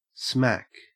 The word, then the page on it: smack: Australian English